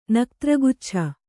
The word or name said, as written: ನಕ್ತ್ರಗುಚ್ಛ